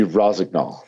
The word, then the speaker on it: Rossignol: Hey, give us the correct pronunciation, please. So we can get it wrong. – Yeah, it would be Rossignol. – Yeah, Rossignol.